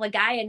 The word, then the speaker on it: Legaia: They're like, it's Legend of Legaia, not Legend of Legia.